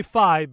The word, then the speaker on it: Fi: I say Fi because they have said that it is supposed to be like hi-fi.